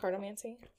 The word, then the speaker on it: cartomancy: Cartomancy? – Yeah.